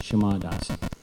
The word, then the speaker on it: Shama: It's a long A, Shama Dasi.